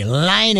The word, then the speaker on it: Laine: Laine is how they told me how to pronounce it.